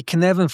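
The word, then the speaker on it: Cynefin: So if you're looking at the Kinevan framework, it's pronounced as if you're saying Kevin and you sneeze in the middle. That's how Dan North explains it.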